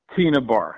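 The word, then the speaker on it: Tena Bar: Is that pronounced right? Tana or Tina Bar? – Tina Bar is Tina Bar's how it's pronounced.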